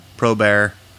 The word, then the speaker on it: probare: It says that proofing bread comes from the old French prouver, which comes from the Latin probare. – That Latin was tight, by the way. Your pronunciation was excellent.